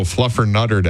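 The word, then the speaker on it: Fluffernutter: Happy National Flutter Mutter Day. – Excuse you? – Fluffer Mutter Day. – Nope. Try again. – Not for you? – You're still saying it wrong. – Oh, Fluffer Nutter Day.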